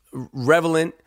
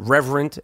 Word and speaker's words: Revenant: I was real happy that Leonardo DiCaprio won for Revenant. I feel like every time I say the title of that movie, I pronounce it differently. I know I've called it Reverent. I know it's not that.